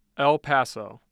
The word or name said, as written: El Paso